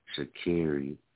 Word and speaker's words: Sha'Carri: Start learning how to pronounce her name. It's Sha'Carri.